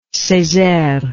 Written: Cesaire

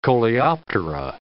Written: Coleoptera

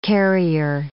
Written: carrier

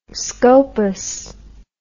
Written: scopus

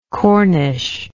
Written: corniche

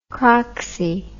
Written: craxi

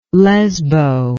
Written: lesbo

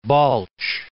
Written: Balch

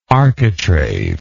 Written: architraved

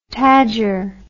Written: tadger

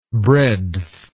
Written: breadthless

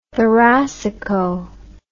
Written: thoracico

